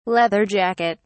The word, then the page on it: leather jacket: British English